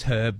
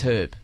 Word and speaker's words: herb: And it is herb, by the way. That's the Australian and UK pronunciation. – It is herb.